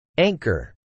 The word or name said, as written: anchor